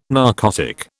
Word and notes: narcotic: British English

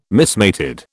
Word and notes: mismated: British English

